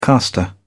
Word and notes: castor: British English

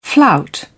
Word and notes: flout: British English

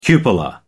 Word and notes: cupola: American English

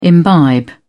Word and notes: imbibe: British English

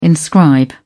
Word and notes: inscribe: British English

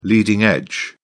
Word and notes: leading edge: British English

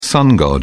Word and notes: sun god: British English

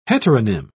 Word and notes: heteronym: British English

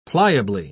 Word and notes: pliably: British English

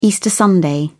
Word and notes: Easter Sunday: British English

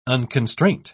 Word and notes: unconstraint: British English

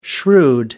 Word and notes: shrewd: American English

